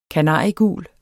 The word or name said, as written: kanariegul